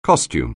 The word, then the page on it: costume: anglais